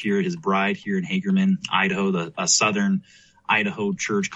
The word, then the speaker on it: Hagerman: Hagerman.